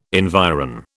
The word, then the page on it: environ: British English